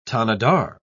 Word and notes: tanadar: British English

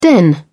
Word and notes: den: British English

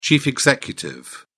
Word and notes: chief executive: British English

